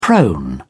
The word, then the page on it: prone: British English